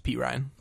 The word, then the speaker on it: Perine: Just from like past experience with, is it Samaje Perine? Have I been saying it wrong this whole time? – Yeah, it's Perine.